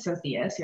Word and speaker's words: Sophias: So I'm Sydney Sophias. You actually pronounced it perfectly. Everybody butchers my last name. It's Spanish.